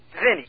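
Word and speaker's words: Vinnie: My name's Vinny. – Yeah, name's what? – Vinny.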